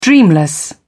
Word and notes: dreamless: British English

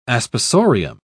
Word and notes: aspersorium: British English

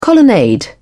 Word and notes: colonnade: British English

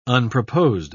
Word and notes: unproposed: British English

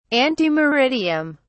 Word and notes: ante meridiem: British English

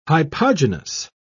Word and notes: hypogynous: British English

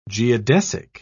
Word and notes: geodesic: British English

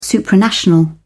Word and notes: supranational: British English

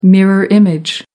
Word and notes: mirror image: American English

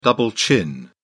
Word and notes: double chin: British English

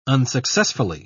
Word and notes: unsuccessfully: British English

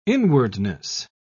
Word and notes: inwardness: British English

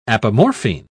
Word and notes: apomorphine: British English

